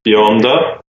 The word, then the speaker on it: bionda: In Italian, it's pronounced Bionda.